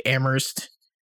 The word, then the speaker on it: Amherst: But yeah, the whole thing, like Amherst, where only the H is silent.